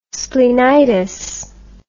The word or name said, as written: splenitis